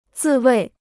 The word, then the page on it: 自卫: zì wèi